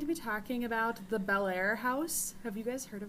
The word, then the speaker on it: Bellaire: So, that's what it sounds like, but it's not spelled like that. Like, it's one word. So, B-E-L-L-A-I-R-E. Bellaire.